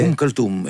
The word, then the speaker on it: Umm Kulthum: Esse músico que eu não vou ousar pronunciar, que tu disseste anteriormente, é Umm Kulthum, é uma cantora egípcia. – Ah, Umm Kulthum, sim.